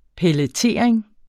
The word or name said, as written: pelletering